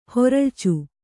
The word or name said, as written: ಹೊರಳ್ಚು